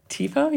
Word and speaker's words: tifo: TIFO? – Yeah.